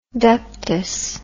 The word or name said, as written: ductus